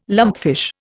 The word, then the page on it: lumpfish: Indian